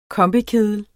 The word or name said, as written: kombikedel